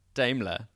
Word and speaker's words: Daimler: Daimler.